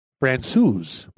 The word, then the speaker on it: Francouz: Francouz.